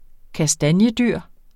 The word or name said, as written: kastanjedyr